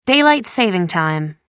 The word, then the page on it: daylight-saving time: American English